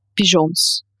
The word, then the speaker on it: Pigeons: Мне тут сказали, что на самом деле красиво будет произносить пижонс на французский манер.